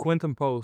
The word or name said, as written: Quinten Post